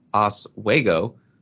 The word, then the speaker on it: Oswego: Also, getting the tight end out of Lake Oswego, it's pronounced Oswego, is huge.